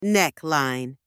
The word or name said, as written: neckline